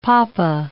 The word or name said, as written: papa